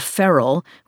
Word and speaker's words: ferrule: One, for example, was an obscure part of an umbrella called a ferrule, which is spelled F-E-R-R-U-L-E, but turns out to be pronounced like an animal that's gone wild.